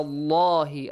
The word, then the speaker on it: Allah: Ala Allahi. Allah is pronounced with a light or heavy sound. Which heavy. – One? Heavy sound.